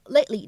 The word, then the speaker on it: lately: 那么当中的 t 这样一个音呢，它其实只是做了口型，并没有非常清晰的读出来。所以呢，我们应该读成 lately，lately。然后呢，在这样一个单词 lately 的前面呢，我们加上一个 lot，这个时候呢，又形成了一个不完全爆破的处理，所以呢，我们可以读成 a lot lately，a lot lately。